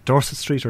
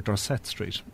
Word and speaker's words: Dorset Street: For example, we have two ways of saying Dorset Street or Dorset Street. The two contrasting pronunciations are to do with the presence of a Norman French-speaking population in the city of Dublin